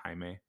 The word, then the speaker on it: Jaime: Did you know that my last name is spelled J-A-I-M-E? Okay. Did you know it's pronounced Jaime? – No way.